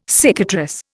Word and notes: cicatrice: Indian

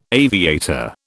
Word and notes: aviator: British English

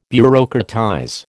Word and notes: bureaucratize: American English

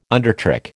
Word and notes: undertrick: American English

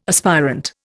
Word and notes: aspirant: Indian

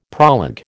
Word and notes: proleg: American English

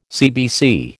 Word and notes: CBC: American English

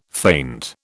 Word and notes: faint: British English

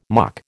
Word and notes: mock: American English

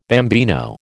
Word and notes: bambino: American English

